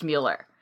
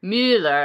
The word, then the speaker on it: Mueller: Well, and if you were going by strict rules of German pronunciation, it should be pronounced Mueller. – Right. – Because the U-E combination is the same as the umlaut over the U, which is a Mueller as opposed to Mueller.